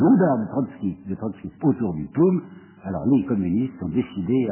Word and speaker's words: POUM: Or, le POUM et OUM, Parti Ouvrier d'Unité Marxiste, et ils vont prononcer Poum, c'est ça, c'est dérisoire.